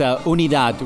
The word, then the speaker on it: Unidad: It's Unidad, which means unity in Spanish. – Unidad. So I will stop calling it Unidad.